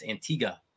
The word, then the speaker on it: Antigua: I tell you out there in YouTube land, if you ever get a chance to go to Antigua, which we had to learn that that's actually how you pronounce it. We thought it was Antigua. They were like, "Hey, you're saying it wrong," and we were like, "What?